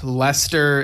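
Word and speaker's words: Leicester: So, just real quick, on the Drum Talk TV page, we got Wayne Sims, who says, hi, it's Wayne here, and Lester is pronounced as Jester in the UK.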